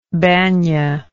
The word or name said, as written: bania